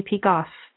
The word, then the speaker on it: Peikoff: If you want the link to this article, you can find it on my personal page on Facebook, Amy Peikoff. Last name is spelled P as in Peter, E-I-K-O-F-F.